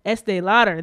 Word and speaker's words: Estée Lauder: The lipstick brand that contains leads are Christian Dior, Lancôme, Clinique, YSL, Estée Lauder. I don't know if I said that right. Estée Lauder.